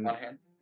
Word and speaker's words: Monaghan: The USS Mohanag- uh, Monaghan. Monahan. Monahan. Sorry, yeah, I was about to mispronounce that one pretty bad there.